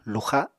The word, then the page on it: luxa: Apatani